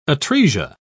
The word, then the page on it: atresia: British English